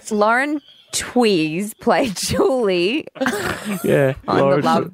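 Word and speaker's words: Tewes: This person said, fact check from yesterday, Lauren Chews is actually pronounced Tweez.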